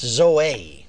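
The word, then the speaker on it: Zoe (ζωή): Now the Greek word here translated life is Zoe. It's spelled Z-O-E. It's pronounced Zoe.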